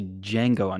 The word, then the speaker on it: Django: This week we watched Django Unchained, which is actually Django Unchained because the D is silent, hillbilly.